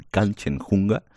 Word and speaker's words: Kanchenjunga: ¿He pronunciado bien Kanchenjunga? – La has pronunciado perfecto. La verdad es que no es una palabra fácil, pero la has pronunciado muy bien.